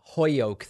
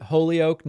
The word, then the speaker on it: Holyoke: I'm not into this whole Hoyoke thing. I pronounce it Holyoke.